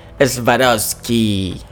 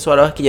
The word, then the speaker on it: Swarovski: Bueno, estos zapatos son en colaboración con la marca de joyería Swarovski o Swarovski, como la mayoría de las personas las, conocemos, pero desde que a mí una persona me corrigió y me dice, no se dice Swarovski, yo digo Swarovski.